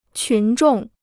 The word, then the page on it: 群众: qún zhòng